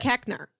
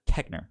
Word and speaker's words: Koechner: David Koechner. – Koechner.